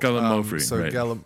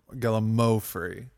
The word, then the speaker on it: Gallimaufry: The emphasis was on the second syllable, or the third syllable. – Gallimaufry, Gallimaufry.